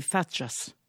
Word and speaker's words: facha: Car Dona Paura fait partie de ces personnes que, dans le village, par un raccourci éloquent, on appelle des fachas. Facha est un mot qui, prononcé avec le « tche » espagnol, se lance comme un crachat.